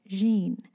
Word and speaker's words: Jeanne: Well, technically back then she pronounced it the French way, Jeanne.